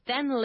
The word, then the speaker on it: than: Living in the city center maybe, is more expensive than living in the countryside. Okay, now then, T-H-E-N, okay, is different, and there's a difference in pronunciation. Than and then.